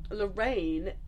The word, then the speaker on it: L'Rain: Last week or the week before, I went to Cardiff to go see Big Thief, and the support act was this band called Lorraine. Lorraine is the like experimental music project of Taja Cheek. The band is called Lorraine because it's the name of her late mother. It's spelled L'Rain.